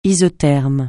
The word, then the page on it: isotherme: francais